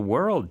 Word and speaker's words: world: That means the world to me. – 자, 발음하기 어려운 단어. – World. W-R-O-L-D. Ignore the O. – 맞아요. – W-O-R-L-D.